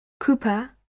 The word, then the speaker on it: Cooper: My name is Barbara Cooper. I'll spell it for you. Barbara, B-A-R-B-A-R-A, Cooper, C-O-O-P-E-R.